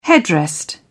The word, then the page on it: headrest: British English